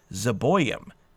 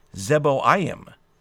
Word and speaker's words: Zeboiim: Like, for example, the audio scriptures will pronounce Zeboiim, using the O and the I to create the oi sound, and then the other I separate. You would be completely safe when you see multiple vowels to just pronounce each vowel. So you might look at Zeboiim and say Zeboiim, using two different vowel sounds for those two vowels, zebo, im. Or you could see it in Keter-Leomer.